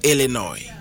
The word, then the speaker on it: Illinois: the word is Illinois Sni is silent and Illinois no the biggest city Ewa Illinois and one of popular Ghana hapa etabu ni dise Chicago no.